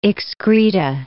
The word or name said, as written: excreta